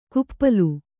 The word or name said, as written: ಕುಪ್ಪಲು